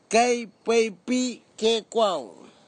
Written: kaipeipikeikwau

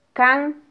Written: kan

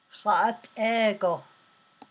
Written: há’at’é-go

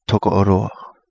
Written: Togo O Lua

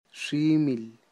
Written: ximil

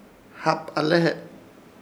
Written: Hapalehe

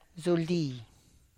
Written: zuldi

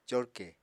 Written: corkè

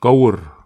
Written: kaur

